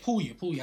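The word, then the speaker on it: Pouya: They got Pooty. They got everybody. Who? Wait, hold on. Who's that last name? – Oh, my bad. Pooya. I said that wrong.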